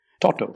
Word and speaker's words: Toto: Toto.